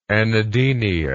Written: anadenia

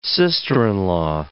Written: sister-in-law

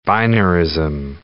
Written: binarism

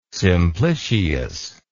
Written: simplicius

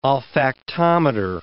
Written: olfactometer

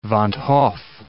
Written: van't Hoff

